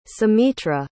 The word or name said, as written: sumitra